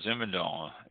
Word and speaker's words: Zinfandel: Z-I-N-F-A-N-D-E-L. Zinfandel. It is pronounced Zin, Z-I-H-N, Zin, fun, F-U-H-N, Del, D-E-H-L, Zinfandel.